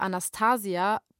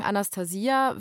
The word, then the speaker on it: Anastasia: Kurz zur Aussprache, Anastasia oder Anastasia, beides ist möglich. Wir sagen Anastasia, wie die meisten Fachleute, mit denen wir gesprochen haben.